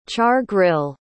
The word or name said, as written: char-grill